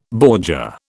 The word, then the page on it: bordure: British English